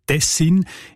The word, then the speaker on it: Tessin: Auch im Hochdeutschen sieht man Tessin. Die Variante mit der Betonung auf den ersten Silben und mit kurzem I, also Tessin, ist seltener.